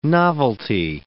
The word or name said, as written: novelty